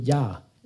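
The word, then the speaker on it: ja (יה): Men de två första bokstäverna, det är ja, uttalar man det.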